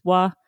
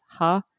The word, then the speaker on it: は: So at least every single time you see the particle, it'll be pronounced as wa. And every other time, it'll always be pronounced as ha.